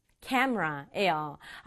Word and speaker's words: camera: 우리말로는 카메라인데요. 영어로 발음할 때는 camera예요. camera. 심지어 어떤 원어민은요. camera 이렇게 두 박자로 발음하는 사람도 있어요. camera. camera. 앞부분에 강조줘서 camera 이렇게 발음해요.